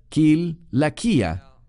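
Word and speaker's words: Kilakia (Κιλικία): Kilakia. Sé que muchos lo pronuncian con un sonido de S, porque tiene una C, pero en griego se pronuncia Kil.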